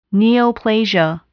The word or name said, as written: neoplasia